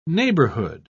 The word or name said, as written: neighborhood